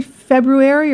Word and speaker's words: February: Because we don't pronounce that R in February.